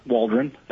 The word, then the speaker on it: Waldron: my name is Burke Waldron. That's spelled W-A-L-D-R-O-N.